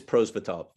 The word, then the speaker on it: Prosvetsov: And that's goalie Ivan Prosvetsov. How do you pronounce that correctly? – It's Prosvetov. Yeah.